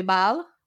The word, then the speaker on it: Baal: But the correct pronunciation, so Oro di Bal, B-A-A-L. And it's Casa di Bal is the winery.